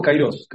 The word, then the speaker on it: kairos: Este, no, tiempos kairos, kairos. Kairos con acento en la, en la O, este, con K.